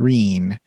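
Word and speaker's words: Rene: And then uh, Scott tells his wife, Reen, it looks like Renee, but it's pronounced Reen, um, that he is not going to be Capcom.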